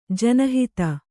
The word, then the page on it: ಜನಹಿತ: jana hata